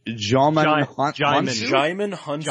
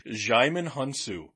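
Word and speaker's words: Djimon Hounsou: And I think it's definitely the case with, how do you pronounce his name? – Jaimon Hunsu. – I don't think I've ever actually said his name. – Jaimon Hunsu.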